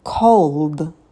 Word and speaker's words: called: Например, «call». Прошедшая форма – это «called». Звук «д».